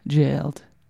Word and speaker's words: Gaoled: From Perth, Australia, it is spelled G-A-O-L-E-D, but they say GAOLED. Easier to say, I think.